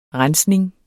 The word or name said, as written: rensning